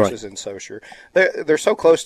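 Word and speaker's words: Saucier: Am I saying that right? – Soshiay? Grand Bear will be in Gulfport. – Well, they have it listed as Soshiay. So Socher? – Socher. – It's spelled one way and it's pronounced another.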